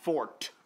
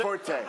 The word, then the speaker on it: forte: Of course, biology is not my fort, which is how you pronounce it. Some people say forte. – No, it's not.